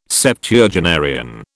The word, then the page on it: Septuagenarian: British English